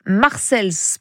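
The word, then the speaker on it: Marseilles: Si, si, Marseille au pluriel est une ville aux États-Unis, située dans l'Illinois, à une centaine de kilomètres de Chicago. On prononce Marcels plutôt.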